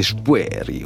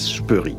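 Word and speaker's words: Spoerri: Il y a une discussion très longue sur la prononciation de son nom. Avec laquelle lui-même s'amuse. Il est suisse, c'est un nom suisse d'origine, donc c'est Spoery ou Spoery, disons à la Suisse que je ne prononce pas bien. Lui préfère finalement la prononciation française, Spoery, qui est celle qui domine un peu dans sa... C'est-à-dire qu'en Allemagne, il se présente comme Daniel Spoery.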